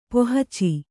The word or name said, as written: ಪೋಹಚೆ